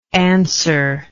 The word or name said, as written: answerless